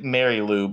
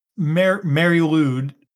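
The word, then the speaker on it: Mari Lwyd: It's like Mary Lou, but with all the wrong letters in it. – You are right. It is Mary Lou.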